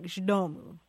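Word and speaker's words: z domu: It's Z space D-O-M-U. And it's pronounced something like Zdomu.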